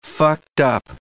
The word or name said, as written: fucked-up